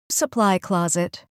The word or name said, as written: supply closet